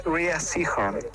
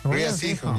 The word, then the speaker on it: Rhea Seehorn: Saludos, amigos. Se pronuncia Rhea Seehorn. – Ah, no. – Rhea Seehorn, ya.